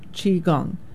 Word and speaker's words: Qigong: It's Q-I and then Gong, G-O-N-G. It's like Tai Chi, but simpler. Qigong, Q-I, you could pronounce it Qi, Qi, and then Gong, G-O-N-G.